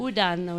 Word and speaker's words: Udaan: Udaan, oui. – Donc, ça s'écrit quand même U-D-A-A-N pour ceux qui chercheraient les infos.